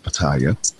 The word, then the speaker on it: Petaja: His first name is E-M-I-L, last name P-E-T-A-J-A. Back many, many years ago when I was reading him, I came up with my own pronunciation. I was calling him Emil Pattaya, but according to Wikipedia, it is pronounced Pattaya.